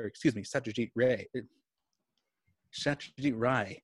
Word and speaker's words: Satyajit Ray: I've I've looked into this because it's hard to say his name, but it's spelled Ray R A Y, but it's pronounced Ray.